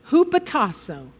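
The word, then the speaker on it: hupotasso (ὑποτάσσω): Our English word submit is translated from the Greek word, and believe it or not, this is pronounced hupotasso. Hupotasso.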